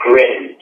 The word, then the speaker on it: grin: You spell it like grin, G-R-I-N, but you say it the French way, it's GRIN.